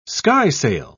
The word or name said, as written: skysail